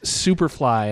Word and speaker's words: Sooperphly: Yeah, Joel's is Superfly, and that is spelled SOOPERPHLY.